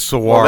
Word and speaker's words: Saguaro: Let's get it right. The G is silent. – Oh, am I saying it wrong? Saguaro. Is it Saguaro? – Yeah. It's Saguaro.